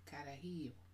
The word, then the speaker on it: carajillo: So I'm having a on the side note, I'm having a carajillo. Did I say it right? – Carajillo.